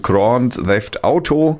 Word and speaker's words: Grand Theft Auto: Das wäre gewaltverherrlichend äh, es heißt äh, ausgesprochen Grand Theft Auto.